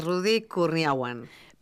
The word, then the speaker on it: Kurniawan: Rudy Kurniawan.